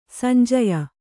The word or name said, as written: ಸಂಜಯ